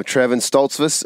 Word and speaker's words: Trevon Stoltzfus: All right, so now on the phone we have Trevon Stoltzfus of Outback Outdoors and Inspired Wild Podcast.